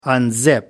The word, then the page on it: unzip: American English